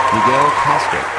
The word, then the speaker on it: Miguel Castro: Miguel Castro.